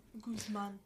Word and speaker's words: Guzman: Joaquin El Chapo Guzman which is not how you pronounce his name. – Guzman. – Yeah, thank you.